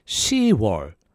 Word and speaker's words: 10월: So it's actually pronounced as 10월. 10월. 10월.